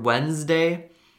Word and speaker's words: Wednesday: You can hear the second D, but the word Wednesday, uh, the first D is silent, and also there is like a there's like a Z sound, which is sort of weird because it's supposed to be an S sound.